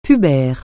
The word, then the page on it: pubère: francais